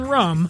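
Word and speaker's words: Rhum: That H in rum is a tip of the hat to the French Caribbean where it's sourced. And as I like to say, the H is silent, but I promise you, you won't be.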